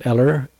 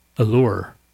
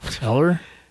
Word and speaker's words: Ellur: E-L-L-U-R. – Allure. Is it Allure? – No. – Eller? – It's like, I guess it's like Ella, but like with Eller, you know? – Okay.